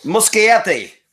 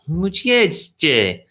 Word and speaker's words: Muschietti: New Andy Muschietti, I think is how you pronounce his name. – Muschietti. – Muschietti.